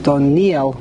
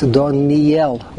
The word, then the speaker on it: Doniel: It's Sere, not like it is commonly pronounced Doniel with a chirik under the nun, and the yud is a chirik mole. It's not. That's incorrect. It's Doniel.